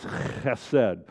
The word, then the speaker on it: chesed: But I believe that with agape love, by the way, the Hebrew word for that is chesed. I usually don't use it because I have to spit on the first three rows to say it correctly with the guttural sound. But this chesed is, I will work for your success even when you're screwing up.